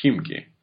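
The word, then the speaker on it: Khimki (Химки): Well, it's pronounced Khimki.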